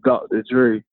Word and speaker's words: Dockta Dre: My name is Andre, but I go by a stage name as Dr. Dre, D-O-C-K-T-A-D-R-E. It sounds similar to the, you know, the beat maker Dr. Dre, but I had to spell it a little different.